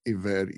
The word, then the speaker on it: eved: was ebed, e b e d, or pronounced eved, e v e d.